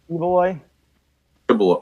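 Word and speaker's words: Quiboloy: How's it pronounced? – Quiloboy. – Oh, Quiloboy.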